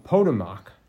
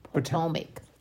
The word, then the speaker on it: Potomac: So uh, in this case, for instance, uh, 34 across uh, read tributary of the uh, the uh, Potomac. No, that's not how you pronounce it. Potomac. Thank you.